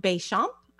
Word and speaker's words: Béchamp: But then we had, and I can't pronounce his last name correctly, probably Antoine Béchamp. I don't speak French. – Yes, you got it.